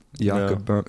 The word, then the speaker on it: Boehme: Do you know Jakob Boehme? It's spelled like B-O-E-H-M-E or something.